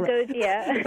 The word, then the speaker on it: hypnagogia: Hypnagogia.